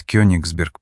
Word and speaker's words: Кёнигсберг: Под Кёнигсберг прибыли, делая ударение на «и», — ответил Данила.